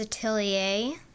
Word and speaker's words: atelier: And its name is Atelier.